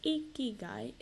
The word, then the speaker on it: ikigai: Pronounced ikigai, is a Japanese concept that means a reason for being.